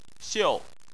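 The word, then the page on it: xiu: xiu4; tone 4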